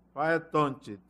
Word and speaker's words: Faetonte: Se escreve como se pronuncia. F-A-E, Faetonte, T-O-N-T-E.